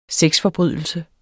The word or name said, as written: sexforbrydelse